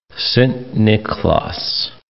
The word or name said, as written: Sint-Niklaas